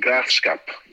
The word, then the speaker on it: Graafschap: So the first game I came over, and the first game was against the Graafschap, that's how you say it.